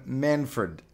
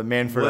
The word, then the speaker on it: Manfred: It wasn't anything specific, but she pronounced the name Manfred and was like, it was like it's spelled Fred. I've seen it that it's supposed to be pronounced Fred, but it's like a soft Fred. It's like you can jumble the letters together.